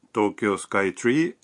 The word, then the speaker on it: Tokyo Sky Tree: La pronunciación de Tokyo Sky Tree es Tokyo Sky Tree.